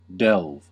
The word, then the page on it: delve: American English